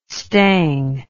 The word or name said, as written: stang